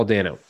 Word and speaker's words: Dano: A lot of people say Paul Dana. – I don't know who Paul Dana is. Do I? – I may be saying his name wrong. – Dano? – Is it Dino? – His Dano. Paul Dano.